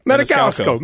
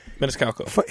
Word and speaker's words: Maniscalco: Maniscalco. Maniscalco. – Maniscalco.